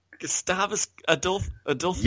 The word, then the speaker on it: Gustavus Adolphus: Gustavus Adolphus.